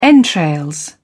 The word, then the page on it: entrails: British English